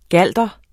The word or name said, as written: galder